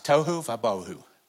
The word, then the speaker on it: tohu vabohu: It's a Hebrew, it's poetry, it's Hebrew, and it rhymes, tohu vabohu.